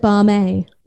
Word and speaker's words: BAME: I mean, I always pronounced it Barmay, like Lame.